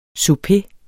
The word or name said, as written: souper